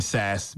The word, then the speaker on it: Sasse: Ben Sass, Ben Bass. It all rhymes with ass.